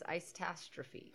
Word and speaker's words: Icetastrophe: Tonight we're watching Christmas Icetastrophe. – Icetastrophe. – I said it right the first time. Icetastrophe.